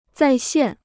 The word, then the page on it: 再现: zài xiàn